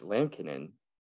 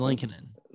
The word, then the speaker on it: Lankinen: It is Lankinen. – Lankinen. – But they were saying Lankinen. – Lankinen. I don't know. On the pronunciation from Hockey Reference, says Lan, L-A-N-K, K-I-H, and then Nen, N-E-H-N.